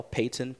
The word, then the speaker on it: Payton: My name is spelled Terrell, T-I-R-R-E-L-L, Payton, P-A-Y-T-O-N.